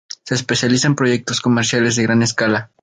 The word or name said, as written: proyectos